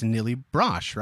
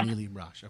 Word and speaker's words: Nili Brosh: It's Nili Brosh, right? Am I saying it right? – Nili Brosh, yeah. – Nili Brosh, okay.